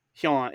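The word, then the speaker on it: Nurarihyon: So this is the Norari Hyon. – Norari Hyon. – Hyon. It's that like Kyoto sound where it's sort of one syllable. Like that, it's the HYO sound.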